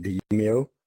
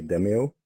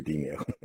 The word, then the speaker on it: Demeo: We decided we're going to pronounce it Demeo. Some might say Demeo, but it should be Demeo.